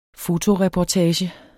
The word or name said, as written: fotoreportage